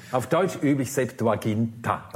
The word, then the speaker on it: Septuaginta: Ja, gewöhnlich sagt man eben, wie es sagt, wir sagen Septuaginta.